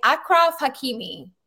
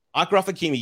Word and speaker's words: Achraf Hakimi: I am saying Achraf Hakimi. – Achraf Hakimi, yes.